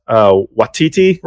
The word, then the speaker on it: Waititi: It's a difficult subject matter overall, but I think Waititi, right? Am I saying his last? I'm going to butcher his name. – Yeah, that's it.